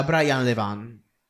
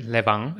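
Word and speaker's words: Brian Levant: Tra questi c'è sicuramente Beethoven, che è uscito nel 1992 ed è diretto da Brian Levan. Detto bene? – Sì, Brian Le- Levan, esattamente, regista appunto della scuola pomeridiana di, di 1, che ha diretto anche altri film di questo tipo.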